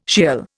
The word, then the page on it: Sheol: Indian